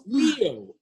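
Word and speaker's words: lío: Leo. L-I, accent on the I, O. It's not your zodiac sign.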